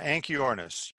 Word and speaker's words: Anchiornis: Anchiornis, is that how it's pronounced? – Yeah, Anchiornis.